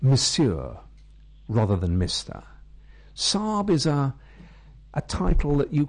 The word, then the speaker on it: Sahib: I called it Sahib. Depending on where you are in India, sometimes pronounced Sahib in the north, often more pronounced Sahib in the south.